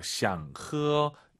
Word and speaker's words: 想喝: 想喝. 일성이니까 이 샹은 절대 뒤에 일성이니까 올라가면 안 됩니다. 반삼성 샹, 허 이게 포인트예요.